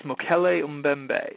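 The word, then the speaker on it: Mokele-mbembe: Ah, yes, Mokele Mbembe.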